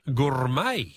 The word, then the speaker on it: gourmet: Gourmet, yes. Correct, G-O-U-R-M-E-T.